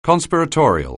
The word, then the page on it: conspiratorial: anglais